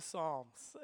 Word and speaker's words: psalm: You probably knew there was a P in front of the word psalm, and not to nerd out on you, but maybe if you're a trivia person, this will come handy later on.